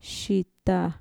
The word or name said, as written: shita